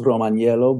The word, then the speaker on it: Romaniello: No, we don't use the I. The I is a silent I. – It's a I assume many, many generations back it was Romaniello, but we're particularly Americanized, and we just say Romaniello.